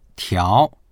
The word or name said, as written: tiao2